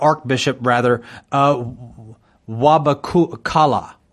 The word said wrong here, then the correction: Wabukala: Wabukala. Um, I, I'm, I'm not familiar with his name. I don't say it very often, so I just wanted to make sure I say it correctly. Wabukala.